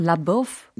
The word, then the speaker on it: LaBeouf: Well, LaBeouf. – you know, Buff? Buff is like 몸짱.